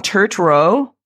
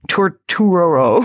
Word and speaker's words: Turturro: You've got Adam Scott, Patricia Arquette, John Turturro. How do you say it? I never say his name right. Turturro. – Turturro, yeah.